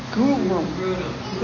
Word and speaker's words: Grudem: Wayne Grudem. How do you say that? Grudem.